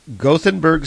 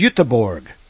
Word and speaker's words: Göteborg: Gothenburg, Sweden. I hate calling it Gothenburg. I really do. Or Gothenburg. I hate that. It's pronounced Göteborg.